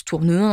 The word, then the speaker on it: Tournehem: Madame Poisson et ses enfants se mettent sous la protection de son amant, le financier Charles-François Lenormand de Tournehem, qu'on prononce Tournehain à l'époque.